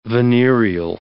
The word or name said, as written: venereal